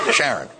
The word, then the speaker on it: Sharon: Well, it's a sign of how desperate things may be now that hopes rest on a woman named Sharon, but pronounced Sharon.